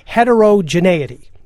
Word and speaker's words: heterogeneity: Heterogeneity.